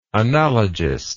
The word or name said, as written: analogistic